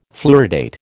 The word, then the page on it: fluoridate: American English